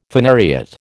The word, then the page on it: phanariot: American English